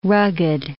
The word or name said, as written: rugged